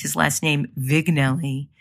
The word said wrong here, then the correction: Vignelli: And he said to me, just as we were starting the interview, he said, no, Debbie, it's Vignelli. And I was like, oh God, I'm such an idiot.